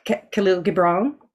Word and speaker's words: Kahlil Gibran: Um, I read a lot of Kahlil, Kahlil Gibran.